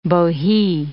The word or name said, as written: bohea